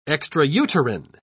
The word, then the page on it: extrauterine: British English